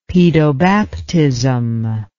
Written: pedobaptism

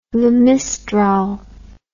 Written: Le Mistral

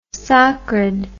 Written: socred

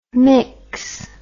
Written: myx

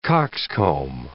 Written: coxcomb

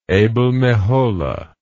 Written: abel meholah

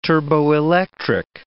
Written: turboelectric

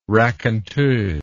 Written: raconteuse

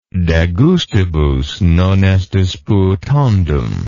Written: de gustibus non est disputandum

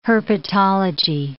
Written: herpetology

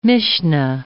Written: Mishnah

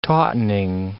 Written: tautening

